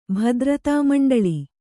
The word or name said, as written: ಭದ್ರತಾಮಂಡಲಿ